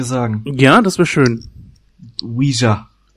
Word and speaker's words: Ouija: Es wird tatsächlich Luigi ausgesprochen, wie auch immer der E-Laut da am Ende reinkommt. Denk an Luigi aus äh, Super Mario Bros. und nimm das L weg. So wird das ausgesprochen.